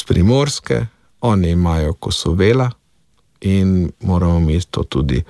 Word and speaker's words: Kosovela: Sam osebno sem tudi dejansko zagovornik, da naj bo naglas na tistem mestu, kjer se tudi izgovarja v določenem družinskem deblu oziroma pokrajini. Tako da bi vsekakor moral biti Kosovela, to bi morala biti prva varianta.